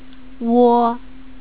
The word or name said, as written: wo